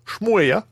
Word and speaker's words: Schmooeya: Je sais ça se prononce. S-C-H-M-O-O-E-Y-A. Shmoya.